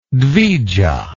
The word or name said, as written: dvija